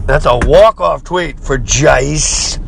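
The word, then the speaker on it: Guice: Gyce, the running back, Gyce, G-U-I-C-E. I believe it's pronounced Gyce.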